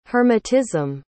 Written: hermetism

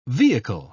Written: vehicle

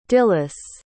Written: dyllis

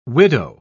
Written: widow

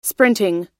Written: sprinting

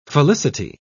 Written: felicity

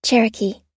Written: cherokee

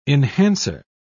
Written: enhancer

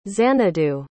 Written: xanadu

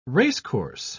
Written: racecourse

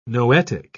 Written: noetic

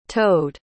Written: towed

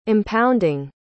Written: impounding

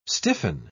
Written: stiffen

